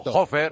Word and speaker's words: Fraunhofer: Pues según el Instituto Fraunhofer, ¿oh? No sé si lo pronunció bien, perfecto. Fraunhofer.